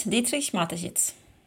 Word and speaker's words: Dietrich Mateschitz: heter han Didri, Dietrich Mateschitz uttalsmässigt? – Precis, Dietrich Mateschitz.